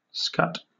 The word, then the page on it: scut: British English